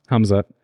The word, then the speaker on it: Khamzat: And do we know how to actually pronounce it? Is it Khamzat? – Khamzat. – Khamzat? – Hum. – Sounded pretty confident. – Like, yeah, I'm going to believe in this one.